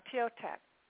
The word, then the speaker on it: Thoth: Now, Thiotet, it's spelled T-H-O-T-H.